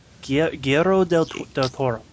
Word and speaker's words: Guillermo del Toro: Gil del Toro. I can't ever say his name. – Guillermo del Toro.